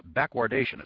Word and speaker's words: backwardation: He says, over the past few weeks, there have been a lot of articles on the Internet about backwardation. It's spelled B-A-C-K-W-A-R-D-A-T-I-O-N.